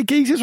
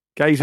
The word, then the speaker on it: geysers: Do you say geezers or is it geysers? – Geysers, geezers. Eh. – Gazers. – I think Americans say gazers.